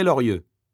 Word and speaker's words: Lorieux: Morvan, ça s'écrit M-O-R-V-A-N. – Eh bien moi, c'est André Lorieux. L-O-R-I-E-U-X. – Alors moi, je m'appelle Philippe Fernandez. Alors Fernandez, c'est F-E-R-N-A-N-D-E-Z.